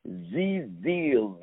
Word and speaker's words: ZHIZDEALS: great application called Z-Deals. Let me spell it again. Uh, Z as in zebra, H-I-Z, deals with an S at the end.